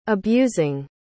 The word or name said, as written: abusing